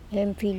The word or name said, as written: empHil